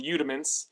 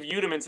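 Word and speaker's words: Youdiments: It's got the same name as my YouTube channel, Udiments. And what does that spell that so people can follow? – It's Y-O-U-D-I-M-E-N-T-S. So it's like rudiments.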